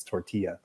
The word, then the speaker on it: tortilla: That'd be like saying tortilla. It's tortilla. Come on now.